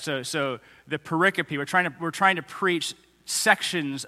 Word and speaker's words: pericope: It's spelled like periscope without an S. So you'll probably mispronounce it pericope for a long time, like I did, until you hear someone else say it and you go, oh, okay. So pericope.